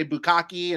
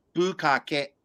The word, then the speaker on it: bukkake: And if anybody's wondering the pronunciation, I've heard people say bukkake and stuff like that. It's bukkake. Bukkake.